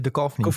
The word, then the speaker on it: Duchovny: Zelfs David Duvinci, ik kan zijn naam niet uitspreken, maar hij speelde... – Duchovny. – Hij speelde Fox Mulder in de X-Files, om even zo te zeggen. – David Duchovny.